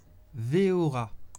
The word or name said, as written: veora